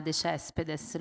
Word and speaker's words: de Céspedes: Alba de Céspedes, la possiamo pronunciare anche all'italiana, de Céspedes, l'ho sentita negli anni pronunciare in entrambi i modi, perché naturalmente le sue origini erano cubane, quindi il suo cognome in origine era spagnolo, però essendo lei nata a Roma, anche da madre italiana, è stato molto frequentemente detto in italiano.